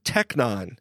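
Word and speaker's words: technon (τέκνον): This is Greek word is translated children usually, and it's technon. Y'all say that after me. Technon. – Technon. You have to say it like the Strong's G. – Strong's 135. – G. 135. Yeah, technon sounds like, that sounds like what you put in your gas, man.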